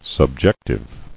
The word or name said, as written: Subjectivity